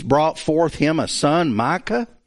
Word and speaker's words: Micha: It's spelled differently elsewhere, but here it's spelled M-I-C-H-A, Micah. And that's the way that would be pronounced. It's the back of the throat K instead of the front of the mouth K.